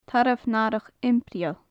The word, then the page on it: tarbh nathrach impireil: gaelic